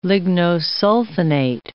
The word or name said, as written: lignosulfonate